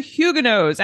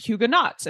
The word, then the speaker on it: Huguenot: It is Huguenot and not Huguenots, as I had been saying, and which you may have been tempted to say as well. This week, we're exploring the arrival of Huguenots to England in Shakespeare's lifetime.